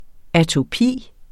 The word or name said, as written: atopi